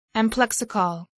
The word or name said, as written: amplexicaul